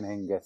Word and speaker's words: Hingis: Start when she was like 14, I remember seeing Hingis start.